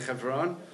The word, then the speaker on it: Hebron: I don't know, how do you say Hebron? Is it okay just to say Hebron? Okay.